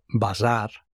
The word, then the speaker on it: Bazaar: La herramienta se llama Prom Bazar, como bazar, pero con dos As, así pronunciado en plan bazar.